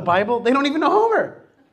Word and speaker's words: Homer: I'm like, Homer. I go to the Russian. I'm like, Gomer.